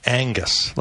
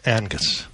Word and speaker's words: Angus: Because people mispronounce it in this country. They say Angus, like Ang Lee, you know, the director. – Oh, yeah. – They say Angus. It's not Angus. It's Angus. – Angus. – Angus. – Angus. – A-N-G-U-S. Oh, A-N-G-U-S.